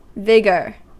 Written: vigor

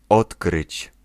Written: discover

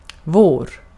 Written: bahar

